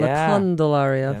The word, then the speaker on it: La Candelaria: La Candelaria. – That's right. – Say that correctly.